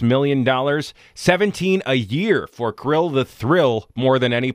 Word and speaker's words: Kirill: And then it's like K-I-R-I-L-L. And I thought, is that Kryl? Is it Kryl? Like the little animals in the sea? Or is it Kryl? Like two syllables? Or is it kind of a syllable and a half? Where the first I is it, you know, tell you what, if it rhymes with thrill, you're probably right.